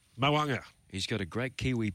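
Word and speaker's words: Mo'unga: He organised that interview with Glenn Climo with Very Elegant, a big tick there, and he tells me the pronunciation, and he should know he's a Kiwi, is Mawunga.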